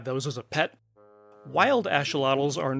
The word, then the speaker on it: axolotl: Now, why am I pronouncing that word like that? It's a AX in the front of the word, and that's pronounced like an SH in the Aztec language. So they're called axolotls, not axolotls.